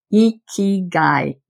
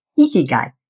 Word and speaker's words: ikigai: Ikigai is how you say it. It's a Japanese word. Ikigai.